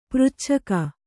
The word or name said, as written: ಪೃಚ್ಛಕ